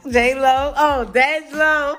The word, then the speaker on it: Dej Loaf: Dej Lo.